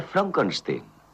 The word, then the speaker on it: Frankenstein: Frankenstein. ¿Me toma el pelo? No, se pronuncia Frankenstein.